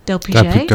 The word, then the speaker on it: d'Alpuget: Blanche d'Alpuget. d'Alpuget.